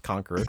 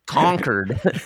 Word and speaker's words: Concord: It's spelled Concord, yeah. But it's pronounced Concord, right? – Concord.